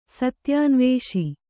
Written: ಸತ್ಯಾನ್ವೇಷಿ

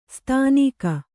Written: ಸ್ಥಾನಿಕ

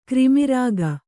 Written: ಕ್ರಿಮಿರಾಗ

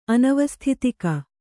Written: ಅನವಸ್ಥಿತಿಕ

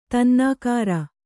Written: ತನ್ನಾಕಾರ

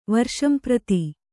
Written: ವರ್ಷಂಪ್ರತಿ

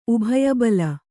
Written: ಉಭಯಬಲ

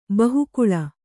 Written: ಬಹುಕುಳ

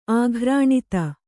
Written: ಆಕೀರ್ಣತೆ

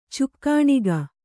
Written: ಚಿಕ್ಕಣಿಕೆ

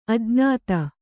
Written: ಅಜ್ಞಾತ

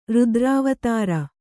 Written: ರುದ್ರಾವತಾರ

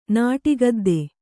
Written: ನಾಟಿಗದ್ದೆ

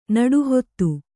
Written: ನಡುಹೊತ್ತು

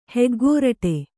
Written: ಹೆಗ್ಗೋರಟೆ

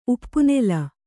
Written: ಉಪ್ಪುನೆಲ

